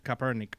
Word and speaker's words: Kaepernick: Colin Kaepernick. Kaepernick, gracias, no sabía pronunciar el apellido de él.